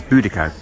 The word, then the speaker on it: Boudicca: Boudicca, Boadicea, on stage here at the Globe, it's being pronounced as Boudicca.